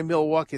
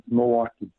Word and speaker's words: Milwaukee: If you know Milwaukee, you don't say Milwaukee. It's Milwaukee, right, Ron? – Yes, it's Milwaukee.